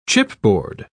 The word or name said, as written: chipboard